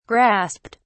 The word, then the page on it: grasped: British English